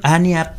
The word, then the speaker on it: Anya: Bueno, ania Anya, perdón, o Anya, no sé cómo pronunciarlo, Anya Taylor Joy, es una de esas actrices sobre eh, la cual tenemos altísimas expectativas.